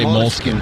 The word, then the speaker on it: Moleskine: Moleskine. – They're all Italian. Of course they pronounce it like that. – They got a few Scandinavians. – The Americans say Moleskine.